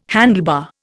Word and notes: handlebar: Indian

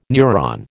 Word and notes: neuron: American English

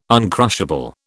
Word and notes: uncrushable: British English

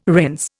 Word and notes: rinse: Indian

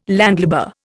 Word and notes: landlubber: Indian

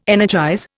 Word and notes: energize: Indian

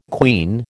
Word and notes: queen: American English

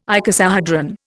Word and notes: icosahedron: Indian

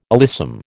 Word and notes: alyssum: American English

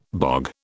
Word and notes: bog: British English

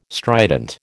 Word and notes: strident: American English

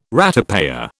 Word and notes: ratepayer: British English

